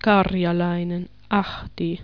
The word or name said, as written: Karjalainen, Ahti